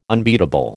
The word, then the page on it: unbeatable: American English